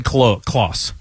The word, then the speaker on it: Klose: You said Klose.